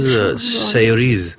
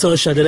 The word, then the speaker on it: Saoirse: No, it's not like that. It's spelled like that, but it's Saoirse. Like, why does she have an R in her name then? – It's an Irish name.